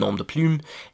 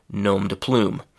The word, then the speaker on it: nom de plume: That's the French pronunciation, nom de plume, and in English we say nom de plume.